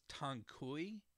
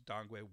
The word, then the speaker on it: dang gui: I'm not going to pronounce two because they actually both sound exactly the same as what I've been saying, Dangui, D-A-N-G-G-U-I. However, they're spelled completely differently. So one is spelled T-A-N-G-Q-U-E-I. And some people will pronounce as Tangkui, but that's not correct. The actual pronunciation is Dangui.